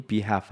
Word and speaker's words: behalf: 당연히 behalf 할 때 L은 묵음이죠.